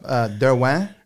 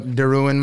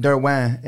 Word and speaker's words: Derouen: I ended up DeRuin my last name because I just like, I'm just going with it. So I say Jason DeRuin, yeah. – But Derwin is actually the French pronunciation.